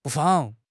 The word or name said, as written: 不妨